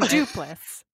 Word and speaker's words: Duplass: Yeah, the Duplass brothers, right? Mm-hmm. – Duplass? – Yeah, and then Duplass. I don't know how to say it. – No, you said it.